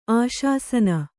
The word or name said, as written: ಆಶಾಸನ